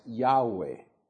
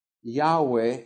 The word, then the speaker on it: Yahweh: Otra palabra que me van a escuchar decir muchas veces, porque ya me acostumbré después de tanto tiempo a usarla, es Yahweh. Yahweh es lo que creemos que era la forma más aproximada a que se pronunciaba el nombre de Dios, el tetragramatón del Antiguo Testamento, es decir, cuatro letras que identifican el nombre de Dios.